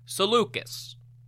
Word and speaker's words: Seleucus: Finally, the commander of the Hypaspists, Seleucus, variously pronounced as Seleucus, Seleucus, however you want to say it, I'm going to say Seleucus was married to Apama, the daughter of the Sogdian warlord Spitamenes.